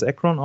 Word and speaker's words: Akron: Äh, und zusätzlich ähm, durften äh, oder durfte der Gewinner dann an der Weltmeisterschaft im Seifenkistenrennen in Ohio Akron, spricht man es Akron aus? – Ja. Akron. – Ja. – A-C-R-O-N, ne?